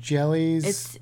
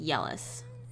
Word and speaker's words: Jelle's: But basically, there's a YouTube channel called Jellies. – It's Yellis. – Yellis. – J-E-L-L-E-S. And, but it's pronounced Yellis.